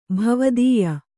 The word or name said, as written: ಭವದೀಯ